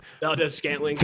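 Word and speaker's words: Valdes-Scantling: Valdez Scantling.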